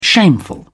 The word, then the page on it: shameful: British English